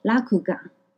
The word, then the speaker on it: 落雁: 这里的点心就叫做金阁，是一款口感绵密细致的落雁，沉鱼落雁的落雁，日文念作拉库嘎。